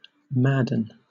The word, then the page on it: madden: British English